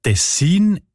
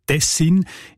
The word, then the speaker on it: Tessin: Die Variante mit der Betonung auf den ersten Silben und mit kurzem I, also Tessin, ist seltener.